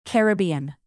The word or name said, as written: caribbean